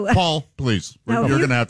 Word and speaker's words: Poll: No, but you know, his name was Paul. – P O. It's pronounced Paul. There's a pronoun, but it was spelled P O L L, as in pole.